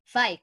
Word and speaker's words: Fike: Fike.